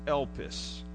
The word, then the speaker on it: elpis: The word hope in the New Testament is from a little Greek word, and it's pronounced elpis. If we transliterate it, that means write it in English so we can spell it or say it. It's E-L-P-I-S.